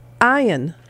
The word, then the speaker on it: iron: And it's supposed to be pronounced iron. – Huh? – Iron. The R is silent.